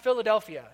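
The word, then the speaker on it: Philadelphia (φιλαδελφία): Because the word is pronounced Philadelphia.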